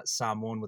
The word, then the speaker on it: Samoan: It is Samoan with the long A. Most folks go with the Samoan.